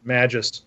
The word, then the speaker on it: Magus: by a guy called Francis Barrett called The Magus or The Magus is the way we would pronounce it.